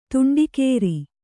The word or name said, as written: ತಂಟೆಕೋರ